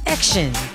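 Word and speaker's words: X:IN: Action, and they are called Action. All right, EX. Sorry, because their group name is called X, colon, IN. So it's pronounced as Action.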